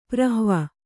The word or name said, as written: ಪ್ರಹ್ವ